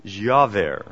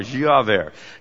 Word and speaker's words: Giaever: I don't know if you read this week, but Dr. Ivar, and I'm pronouncing it Javert, and I don't know, but I figured if I just say it, you'll like it. So there we go. G-I-A-E-V-E-R, Javert.